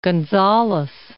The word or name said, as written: Gonzalez